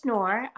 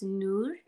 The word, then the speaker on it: Noor: It is Noor. The Arabic pronunciation of it is Noor, but Noor is fine.